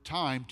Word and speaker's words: thyme: I love the English language because there's this play on words today, if we think about it, as the word time, T-H-Y-M-E, is pronounced time. And so we take the opportunity today to talk about that other word, the T-I-M-E, that time.